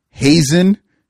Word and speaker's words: Hazen: Now, his real name is Hazen Shirley Kyler. Wow. That is his actual name. It's not spelled like this, but it sounds exactly like Hazing Shirley.